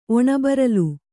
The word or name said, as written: ಒಣಬರಲು